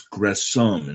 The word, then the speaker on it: Gresham: It's Gresham, incidentally. You don't pronounce the H.